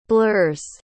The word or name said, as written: blurs